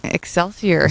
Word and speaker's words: Excelsior: Yeah, Excelsior. – Is there an X in there? – E-X-C-E-L-S-I-O-R.